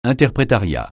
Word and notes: interprétariat: francais

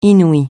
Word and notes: inouï: francais

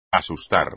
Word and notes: asustar: espagnol

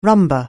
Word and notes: rumba: anglais

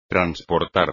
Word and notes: transportar: espagnol

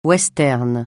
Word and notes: western: francais

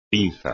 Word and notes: pinza: espagnol

